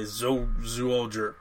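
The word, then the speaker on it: Zyuohger: And then the current season is Zuoger, or Animal Sentai Zuoger. I have trouble pronouncing it, because it's a Z, but it's like Zho. Zuoger.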